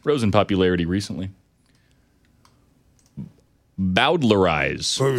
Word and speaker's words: Bowdlerize: Bowdlerize.